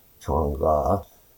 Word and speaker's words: Chang'e: Named after the Chinese moon goddess Chang'e, pronounced Chang'e, the program's first two missions orbited the moon to photograph and map its surface.